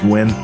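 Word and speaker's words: Nguyen: Yeah, I think it's pronounced Gwen.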